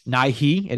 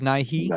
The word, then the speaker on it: Nighy: I never know how to pronounce his name. I haven't heard anyone say it yet. Amy Lou Wood and Bill Alex Nighy. Sharp. Nighy. It is Nighy.